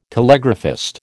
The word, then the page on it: telegraphist: American English